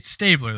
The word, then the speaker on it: Stabler: It's S-T-A-B. L-E-R, isn't it? – It's Stabler. It's Stabler. That's pronounced Stabler.